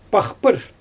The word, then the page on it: paxpyr: Xyzyl